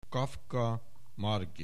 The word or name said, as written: Kaffka Margit